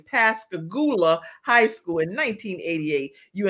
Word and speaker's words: Pascagoula: You graduated from Pascagoula.